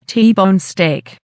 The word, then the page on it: t bone steak: American English